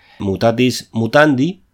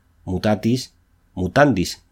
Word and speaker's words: mutatis mutandis: Mutatis mutandi, cuando en realidad se escribe y se dice mutatis mutandis, terminado en S.